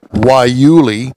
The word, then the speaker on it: guayule: feature a rubber made from guayule, pronounced guayuli, a woody shrub found in the deserts of the Southwest.